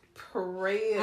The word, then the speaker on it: praetorium: Then they led Jesus from Caiaphas to the praetorium.